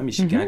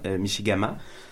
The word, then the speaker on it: Michigan: Euh, dans les lacs, on a le lac Michigan, évidemment, Michigan qui mhm. veut dire lac large en euh, Ojibwe. Euh, puis la, prononciation, c'est Michigan, Michigama.